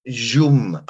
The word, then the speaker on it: Szum: Zum. S-Z-U-M. – Zum.